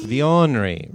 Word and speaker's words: Vionery: Vionery rhymes with ornery, which I am.